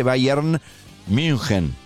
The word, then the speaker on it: Bayern München: Si es Bayern con Y, Bayern München. Si lo vas a pronunciar en alemán. O lo pronuncian en castellano o en alemán.